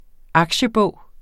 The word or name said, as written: aktiebog